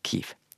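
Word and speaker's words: Kyiv: Men ukrainsk altså Kiev. Det er svaret.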